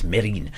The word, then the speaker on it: Mesrine: The S isn't pronounced. – Well, only in the film they keep calling him Mesrine, and he keeps correcting them. No, it's Mérine, played by Vincent Cassel, who is just this swaggering presence all the way through it.